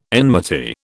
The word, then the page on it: enmity: British English